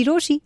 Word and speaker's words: Rochie: मेरा नाम है मिर्ची रोशी. नाम की स्पेलिंग है R-O-C-H-I-E.